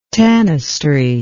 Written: tanistry

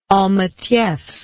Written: almetyevsk

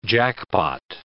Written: jackpot

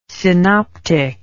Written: synoptic

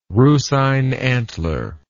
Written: rusine antler

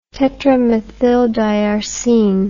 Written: tetramethyldiarsine

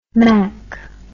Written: mak

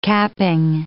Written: capping

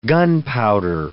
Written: gunpowder

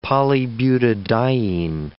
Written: polybutadiene

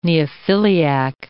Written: neophiliac

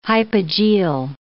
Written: hypogeal

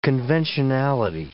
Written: conventionality

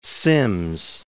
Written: Sims

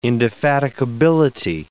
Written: indefatigability

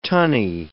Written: tunny

